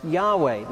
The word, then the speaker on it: Yahweh: You know, I say Lord, and then I say it's the name traditionally known as Jehovah, but more accurately, likely pronounced Yahweh.